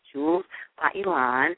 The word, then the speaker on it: Jewels by Elan: That's Jewels by Elan. And I just said it right the first time,